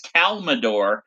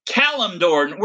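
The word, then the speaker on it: Kalimdor: It's like when Megan used to get mad at me all the time because I would pronounce Calimdor as Calmador. She would say, Jesus Christ, it's Calimdor. Where do you get Calmador from?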